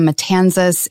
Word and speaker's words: Matanzas: And that's the evening on the Matanzas, right? Am I saying that right? – It is an evening on the Matanzas.